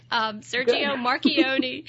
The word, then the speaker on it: Marchionne: Marchionne. Is that correct? – You did good. – Okay. – That's correct. – That's because I married an Italian. Sergio Marchionne.